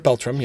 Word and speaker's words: Beltran: Robert Beltran calls, I'm assuming that's the way his name is pronounced. – Robert Beltran, yep.